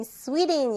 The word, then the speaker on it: Sweden: 선생님, Sweden 이렇게 발음한다고 했었습니다. 우리말로는 스웨덴이지만요.